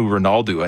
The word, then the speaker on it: Ronaldo: Cristiano Ronaldo, I think, is how you technically put more of an ooh sound on the end of it if you're saying it properly.